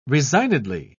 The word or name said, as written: resignedly